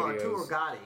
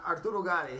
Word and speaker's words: Arturo Gatti: Reminds me of Arturo Gatti. Did I say his name right? – Yeah, Arturo. Arturo Gatti.